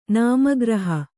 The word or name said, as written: ನಾಮಗ್ರಹ